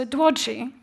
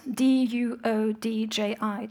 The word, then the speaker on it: Duodji: So, Duodji, which is spelled D U O D J I, Duodji, is in fact the relevant epistemological frame for any maker of material culture in Sapmi.